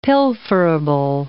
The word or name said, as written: pilferable